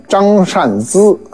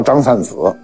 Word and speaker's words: 张善子: 这张善子这名啊，准确发音应该念张善资啊，但行里没这么念啊，都叫张善子，所以你念对了就是念错了，念错了就是念对了。